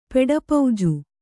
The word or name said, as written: ಪೆಡಪೌಜು